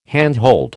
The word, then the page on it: handhold: American English